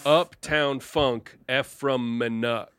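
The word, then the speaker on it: Menuck: It's M-E-N-U-C-K, Menek.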